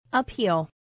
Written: appeal

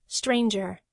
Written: stranger

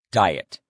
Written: diet

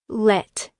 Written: let

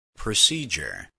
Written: procedure

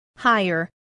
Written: hire

